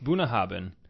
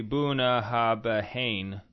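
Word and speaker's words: Bunnahabhain: It's pronounced Bunahaben. – Bunahaben. – But let me tell you, it's not spelled that way. – No. – Okay, it's with like B's and H's and... – It's... Bunahaben. – It looks like it should be Bunahabahane, mm-hmm.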